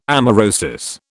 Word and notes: amaurosis: British English

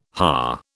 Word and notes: haar: British English

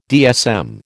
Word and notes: DSM: American English

